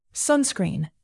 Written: sunscreen

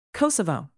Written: kosovo

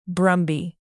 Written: brumby

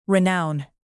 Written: renown

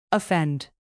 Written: offend

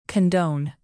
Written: condone

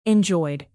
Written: enjoyed

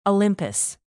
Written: olympus

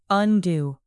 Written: undo

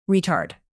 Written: retard

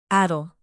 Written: addle

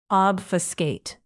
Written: obfuscate

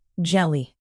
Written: jelly

